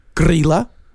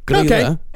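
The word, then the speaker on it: Grýla: These I know I'm actually pronouncing right. – Okay. – There is Grilla and Lepaluthe. – Huh. – Yeah. Grilla and Lepaluthe.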